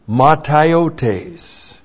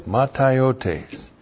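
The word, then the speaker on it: mataiotēs: Blackout of the soul is the believer saying no to Bible doctrine, which then opens up matiotes. M-A-T-A-I-O-T, long E-S, matiotes.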